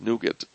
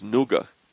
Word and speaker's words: Nougat: Or I'm told the proper pronunciation is Nougat. N-O-U-G-A-T, if you ask people in England. And in fact, I heard this, that the text-to-speech that Google gives you by default in the phone will say it, Nougat.